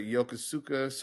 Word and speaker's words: Yokosuka: Uh, Strowman went for the the, uh, and, and if I'm saying this wrong, the, the Yokosaka cutter. I, yeah, it's a Yokosuka. – Yokosuka, like yeah.